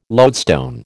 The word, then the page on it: loadstone: American English